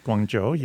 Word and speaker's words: Guangzhou: Guangzhou. – Guangzhou, yes.